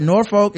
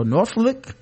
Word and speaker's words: Norfolk: Norfolk is pronounced Norfolk. And he took the O out of FLK. That, just pretend like the second O isn't there. LOL. Well, FLK would sound like flick. So, Norfolk.